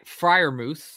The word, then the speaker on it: Freiermuth: If we don't trade any picks, which is impossible, my 11 picks are Jamar Chase at 6, Pat Freiermuth.